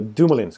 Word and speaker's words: Dumoulin: Dumoulin har ju sett ut som en bra back eh, även i Nej, Dumoulin ska jag säga. Jag har ett obegripligt uttal på Dum Dumoulin. Dumoulin, det kan till och med jag faktiskt.